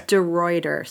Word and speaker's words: DeRuiter: It's DeRuiter.